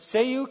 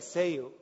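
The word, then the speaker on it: céu: But it's seu. It's spelled C-E-U. And Seu can mean either sky or heaven, depending on how you say it. So it's either Seu or Seu. You're like, what? You just said the same thing twice. No, no, no. I said sky, and then I said heaven.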